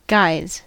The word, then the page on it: guys: /ɡaɪz/